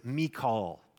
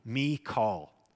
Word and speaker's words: Michal: She's been called Michal, if you will, but literally the Hebrew is Michal. That's the way you say her name, Michal.